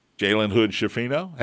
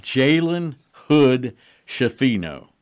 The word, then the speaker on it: Jalen Hood-Schifino: TJD, Race Thompson, Jalen Hood-Schifino.